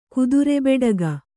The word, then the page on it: ಕುದುರೆಬೆಡಗ: kannada